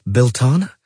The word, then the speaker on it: Beltane: and Beltane is pronounced Beltana.